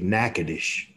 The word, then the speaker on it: Natchitoches: The Louisiana people actually pronounce that city Natchitoches.